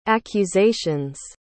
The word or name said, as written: accusations